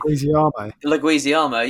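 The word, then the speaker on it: Leguizamo: John Leguizzi. Oh, I can never pronounce his fucking name. – Leguizziamo, yeah.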